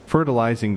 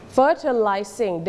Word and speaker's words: fertilizing: Fertilizing the garden. – Fertilizing the garden. – นักเรียนออกเสียงว่า fertilizing หรือ fertilizing ก็ได้นะคะออกได้สองแบบโอเค one more time fertilizing the garden.